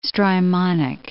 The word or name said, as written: Strymonic Gulf